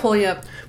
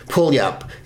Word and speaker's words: Puyallup: Pulyup. – Pulyup. It's spelled Pulyup. – Really? – P-U-Y-A-L-L-U-P. Pulyup, Washington.